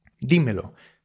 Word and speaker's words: dímelo: En esos casos, si el énfasis recae en la primera, dímelo, dámelo.